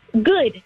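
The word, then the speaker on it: Goode: But my given last name is Good, spelled with an E.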